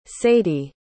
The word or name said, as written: sadie